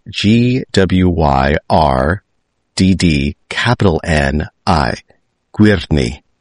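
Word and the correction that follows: Gwyrddni: You're putting an extra syllable in that. It's Gwyrni. – The double D in Welsh is pronounced the, as in there, or then, and then everything else is more or less how it looks and how it sounds.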